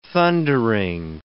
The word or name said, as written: thundering